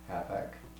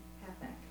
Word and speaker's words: Hathach: Do you know how to pronounce his name? His eunuch? – Hathach. – Okay, Hathach.